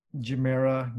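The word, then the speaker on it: Gemera: And it's pronounced Jamera. I thought it would be with a hard G, but no, it's a soft G.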